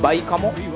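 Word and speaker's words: 梅花藻: バイカモ、梅の、花の藻と書いてですね、バイカモが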